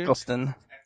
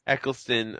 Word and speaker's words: Eccleston: Nomad's gonna review um Lennon Naked, which is just gross, starring Christopher how do you pronounce his last name? Dude? Eccleston. Eccleston.